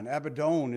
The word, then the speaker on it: Abaddon: If you remember from Revelation chapter 9, Abaddon is really how you say it, is a place for the imprisoned demons who are going to be unleashed during the tribulation period, if you remember.